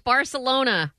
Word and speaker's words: Barcelona: My Spanish teacher in college was from Spain, and she said she would say Barcelona. – Barcelona. – Right. – Say it right, lady. It's Barcelona.